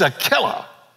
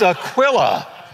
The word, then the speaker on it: Aquila: I looked it up, and I listened today to people pronounce it. It's Aquila.